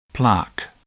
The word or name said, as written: plaque